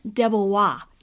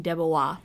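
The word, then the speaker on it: DeBlois: Well, I mean, it's it looks like it would be Dubois if it didn't have the L before the OIS. And so I am going to then go with the Wikipedia pronunciation, which seems to indicate it's Dean Debois, but everyone's welcome to correct us if we're wrong.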